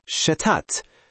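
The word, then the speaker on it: Stadt: شتات شهر تلفظ میشه شتات.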